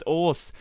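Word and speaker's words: øl: It's pronounced ors.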